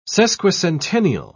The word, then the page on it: sesquicentennial: British English